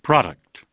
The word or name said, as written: Product